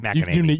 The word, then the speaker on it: McEnany: And by the way, you know where she's is that how you pronounce her last name? – McEnany. You nailed it.